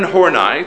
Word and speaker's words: Horney: Karen Horney, this is her first time she comes up, and that's how you pronounce her name, Horney, as if there were an E at the end, and it was Horn, E-Y-E, Horney, that's how you pronounce her name.